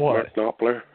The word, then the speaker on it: Knopfler: Uh, I'm a big fan of Mark Knopfler from Dire Straits, if I'm pronouncing his name right. – Mark, Mark Knopfler, yeah.